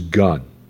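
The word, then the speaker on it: gun (γυνή): The Greek word for woman here is gun, like the barrel of a gun, but it's spelled with an H. So G-U-N-H.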